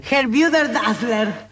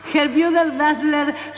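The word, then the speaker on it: Gebrüder Dassler: Te voy a pedir que vos que tenés buena pronunciación de alemán, si lo puedes decir, te lo puse un poco más grande. – Herbüder Dassler. Herbüder Dassler Schuhfabrik. – Muy bien, bueno, fábrica de calzado Dassler.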